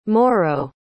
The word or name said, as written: morro